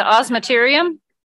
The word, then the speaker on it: osmeterium: So on all swallowtails also have that oscillarium, right? Is that what it's the osmeterium? – Osmeterium.